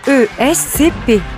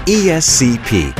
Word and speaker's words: ESCP: ESCP. – ESCP. – ESCP. – ESCP.